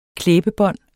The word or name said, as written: klæbebånd